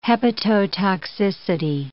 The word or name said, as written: hepatotoxicity